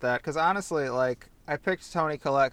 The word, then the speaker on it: Collette: And I also have, I'm going to pronounce this wrong, Toni Collette? The bomb Collette.